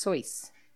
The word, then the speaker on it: Seuss: Seuss was originally pronounced Soyce.